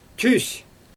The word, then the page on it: tys: Xyzyl